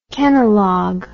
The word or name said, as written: Kenalog